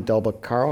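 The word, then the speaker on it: Del Beccaro: Tom DelBacaro, am I pronouncing that correctly? Yeah.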